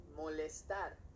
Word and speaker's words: molestar: Okay, I just didn't want to say molester, but it's molestar, not molester.